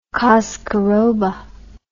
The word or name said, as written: coscoroba